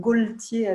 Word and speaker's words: Gaultier: Alors, je vais vous donner un petit peu de contexte sur ce qu'est Jean-Paul Gaultier aujourd'hui. Il aime bien qu'on dise Jean-Paul Gaultier avec le L, donc je vais lui faire plaisir à Jean-Paul.